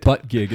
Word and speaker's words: Buttigieg: Uh, Pete Pete Buttigieg, I think that's how you say it. Butt gig is how you say it.